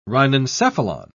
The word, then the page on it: rhinencephalon: British English